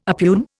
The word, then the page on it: oppugn: Indian